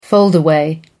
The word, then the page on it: foldaway: American English